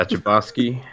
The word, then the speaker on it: Chbosky: Yeah, Chbosky.